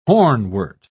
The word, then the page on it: hornwort: British English